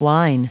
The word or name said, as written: whine